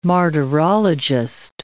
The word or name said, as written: martyrologist